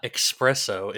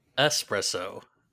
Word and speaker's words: espresso: It is not espresso. It is espresso.